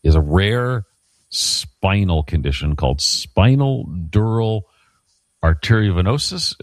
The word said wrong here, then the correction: arteriovenous: No, I'm saying it wrong. Arteriovenous fistula.